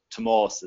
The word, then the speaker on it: Tomás: The Gaelic Irish version of it, Tomás, is how it's pronounced. There's an accent on the A, which elongates it.